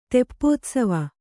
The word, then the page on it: ತೆಪ್ಪೋತ್ಸವ: teppōtsava